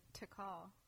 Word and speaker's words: Takal: Takal. Like, you need to call the doctor.